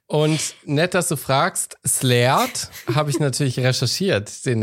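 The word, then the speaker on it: Sleat: Slayed, ausgesprochen halt. – Wie du es ausgesprochen hast, ja. – Mit so einem Strich über dem E. Slayed, ja.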